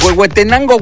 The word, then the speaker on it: Huehuetenango: Huehuetango, Guatemala. No es Huehuetenango, Guatemala. Así es, Huehuetenango, Guatemala.